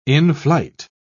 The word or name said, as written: in-flight